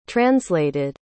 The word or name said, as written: translated